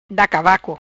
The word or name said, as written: Da cavaco